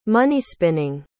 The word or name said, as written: money-spinning